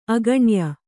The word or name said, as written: ಆಗ್ನೇಯಿ